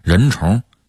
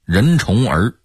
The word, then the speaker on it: 人虫: 比如我写的小说《人虫》，南方人会念成《人虫儿》，这听起来好像我写的是人虫的儿子，这得有多别扭啊！